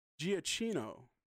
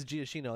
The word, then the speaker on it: Giacchino: And we also got the first look at the score by a composer by the name of Michael Giacchino. And if I'm butchering that... – Giacchino? – Yeah, is that what it is? – I think it's Giacchino, like a maraschino cherry.